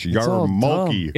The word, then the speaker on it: yarmulke: There's an R. See, that's where they put an R in, and you don't pronounce it. – Yeah. It's yarmulke. – It's a reverse colonel. – It looks like yarmulke. – Yeah. There is an R, and you don't say it.